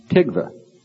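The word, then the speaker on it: tikva (תקוה): In Hebrew, then hope is pronounced tikva.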